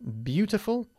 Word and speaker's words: beautiful: ¿Is Sevilla beautiful or ugly? It's beautiful. – It's beautiful. – It's beautiful. Good. No se pronuncia beautiful. It's beautiful. Bu. Okay? Beautiful. Bien.